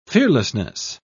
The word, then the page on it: fearlessness: British English